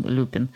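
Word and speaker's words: Lupin: Ну, тут все-таки надо сказать, что вот Римус Люпин, мы некоторое время обсуждали, где у него ударение, но решили, что так как его фамилия явно происходит от слова «люпус», которое на латыни значит «волк», то, наверное, он Люпин.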